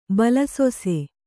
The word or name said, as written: ಬಲಸೊಸೆ